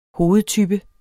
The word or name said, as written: hovedtype